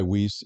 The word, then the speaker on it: Weis: Well, Weiss would be the German pronunciation. Okay. I go by Weiss. It's wrong, but that's what my parents have always used, so right.